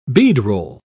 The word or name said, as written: beadroll